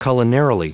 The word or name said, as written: culinarily